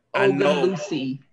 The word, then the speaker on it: Ogunlusi: How do you say it? – All these years, y'all known Wande? Ogunlusi. – Ogunlusi. – Yes. – Ogunlusi.